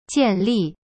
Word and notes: 建立: jian-li

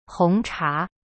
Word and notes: 红茶: hong cha

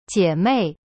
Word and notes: 姐妹: jie mei

